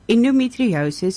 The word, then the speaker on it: endometriosis: So, Sister, this morning we're talking about endometriosis awareness. Am I saying it right? – Yes.